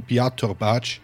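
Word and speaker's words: Biatorbágy: September 1931, als auf dem Viadukt von, jetzt kommt ein ungarischer Ortsname und ich habe mir extra von einem Experten die Pronunciation, die korrekte, geholt. Moment, ich versuch's. Achtung! Das Viadukt von Bihatorbács, etwa 40 Kilometer westlich von Budapest, eine Bombe mit 2 Kilo Sprengstoff explodierte.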